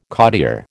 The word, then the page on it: cottier: American English